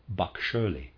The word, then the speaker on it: Bagschirli: Readers aside, I'm going to spell that B-A-G-S-C-H-I-R-L-I. Bakscherli is how I'm going to pronounce that.